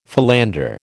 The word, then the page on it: philander: American English